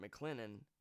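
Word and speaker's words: McClellan: So he was actually sent to Fort McLennan in Alabama, that's how you say it.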